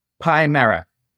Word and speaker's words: Pimera: Is it Pimera? – Pimera. Even its name itself has an interesting sort of roots.